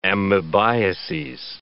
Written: amebiases

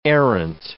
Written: errant

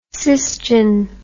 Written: sysgen

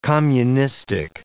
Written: communistic

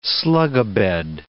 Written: slugabed

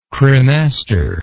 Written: cremasteric

